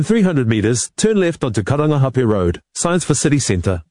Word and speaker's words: Karangahape Road: That was TVNZ's Māori Issues reporter Tianiwa Huri Hanganui driving around Auckland and unimpressed with the navigation voice on the Google Maps app mispronouncing Karangahape Road and other places with Māori names.